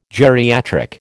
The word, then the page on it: geriatric: American English